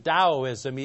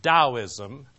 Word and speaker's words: Taoism: And I pronounce it Taoism, even though it's spelled Taoism. It's pronounced Taoism.